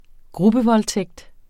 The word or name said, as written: gruppevoldtægt